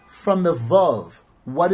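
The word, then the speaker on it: Vav (וו): And when you pronounce the word Vav, it's Vav, as if it were a Vav with a chametz under it and another Vav.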